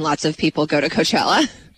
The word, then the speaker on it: Coachella: Coachella has been postponed. – Coachella.